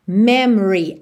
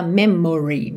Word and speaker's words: memory: Atenção que esse O é praticamente mudo, tá? Você não fala memory. Memory é memory. Memory. Memory. – Câmera lenta. – Memory, tá? Então, memory. E a sílaba tônica é a primeira, me. Então, depois já vai tudo diminuindo.